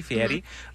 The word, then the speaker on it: Fieri: I say it correctly, Fieri.